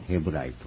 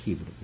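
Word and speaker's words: Hebrew: 日本語的発音でこのヘブライと書いてありますが、ヘブルとも発音する、ヒブル、英語ではですねヒブルと言います。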